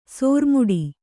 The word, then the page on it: ಸೋರುಮುಡಿ: Kannada